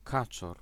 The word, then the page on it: drake: /ˈdrɑːˌkɛ/